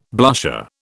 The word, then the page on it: blusher: British English